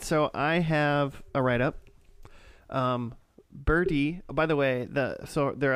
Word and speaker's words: Bertie: B-E-R-T-I-E. – Oh, it's still Birdie. – Birdie. – That's pronounced Birdie.